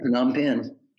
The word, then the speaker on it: Phnom Penh: Please go check out his new book, From Charleston to Phnom Penh. Is that how you pronounce it? – Yeah, Phnom Penh.